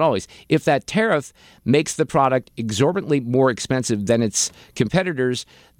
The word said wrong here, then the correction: exorbitantly: Let me put the emphasis on the right syllable there. If that tariff makes the product exorbitantly more expensive than its competitors, then one of two things happens.